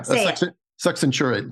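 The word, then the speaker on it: succenturiate: I don't know how to pronounce it. Succinctate lobe? – Yeah. Succinctate lobe.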